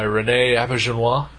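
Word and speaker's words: René Auberjonois: Kenjar Rowe, played by Rene Abergenois.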